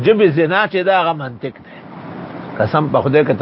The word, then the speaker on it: منطق: اور کہتے تھے میں منطقی ہوں منطقی. تو میں نے بخاری کے ختم میں کہا کہ لفظ منطق ہے منطق نہیں ہے.